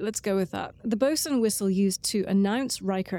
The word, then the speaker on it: boatswain: Well, spelled botswain. – Botswain. – But I think it's pronounced bosun. I don't know. This is a thing I'd find in a D&D book. Pretty sure they say bosun.